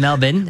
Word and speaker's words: Melbourne: This is how we pronounce it here. It's Melbourne. – We'd like you to know that's Melbourne. It's not Melbourne.